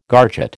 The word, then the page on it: garget: American English